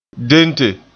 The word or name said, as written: dente